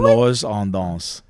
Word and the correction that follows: Alors on danse: Alors on danse.